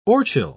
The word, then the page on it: orchil: British English